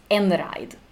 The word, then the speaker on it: Einride: And it's pronounced Enride, right? – That is correct. – Yeah. Enride.